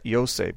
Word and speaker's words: Yose: Mark chapter 6, verse 3 specifically tells us that Jesus had brothers named James, Joseph, although I want to pronounce that Yose, because that's how you pronounce it in the Greek.